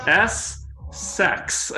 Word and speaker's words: Essex: And they were trying to tell him, S-sex. That's how you pronounce it.